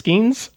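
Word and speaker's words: Skenes: Name rhymes with Skeens. – Yeah. Isn't it just Skeens? – I think so.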